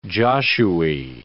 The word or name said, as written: Josue